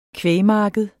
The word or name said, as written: kvægmarked